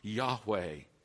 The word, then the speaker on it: Yahweh: And that's Exodus 3:14, and that's a tetragrammaton, the four letters that we think rightly are pronounced Yahweh.